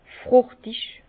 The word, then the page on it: frùchtig: Bas-Rhin; Herrlisheim